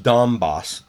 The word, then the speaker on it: Donbass: These troop movements are unsettling, and there are skirmishes in Donbass. – Donbass.